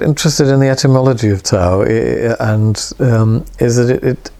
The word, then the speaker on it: Tawe: Tau, T-A-W. It's pronounced Tau, Tau, and it's got a similar, you know, the Chinese word is T-A-W, well, it's in Chinese characters, but the English approximation is T-A-O, but they sound the same.